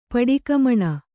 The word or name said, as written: ಪಡಿಕಮಣ